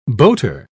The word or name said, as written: boater